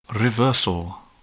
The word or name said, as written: reversal